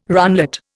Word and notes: runlet: Indian

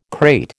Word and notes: krait: American English

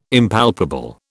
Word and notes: Impalpable: British English

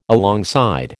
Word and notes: alongside: American English

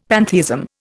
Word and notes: pantheism: Indian

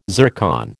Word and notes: zircon: American English

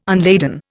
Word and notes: unladen: Indian

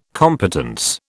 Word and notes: competence: British English

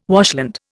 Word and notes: washland: Indian

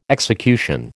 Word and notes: execution: American English

